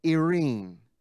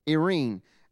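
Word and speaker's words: Irene (εἰρήνη): Now, I don't have it on the notes up on the overhead, but and it's hard to pronounce, but it's spelled E-I-R-E-N-E, almost like the word serene, but without the S, Irene, Irene.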